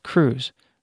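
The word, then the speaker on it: krewes: Parades are held by carnival organizations called crews. It's spelled K-R-E-W-E.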